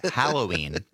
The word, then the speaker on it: Halloween: Yes, Mike in Spring Green called in during the break to thank for correctly pronouncing Halloween. It is a pet peeve of his when right. People say Halloween. – Halloween. – It is an A, not an O at the beginning.